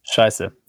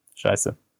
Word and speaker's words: Scheiße: So the book is called Scheiße, We're Going Up. Am I saying that correctly? – Scheiße. Yeah. Scheiße.